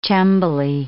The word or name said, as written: cembali